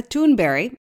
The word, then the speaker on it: Thunberg: And we begin with teenage climate activist Greta Thunberg, as it's pronounced in her native Sweden,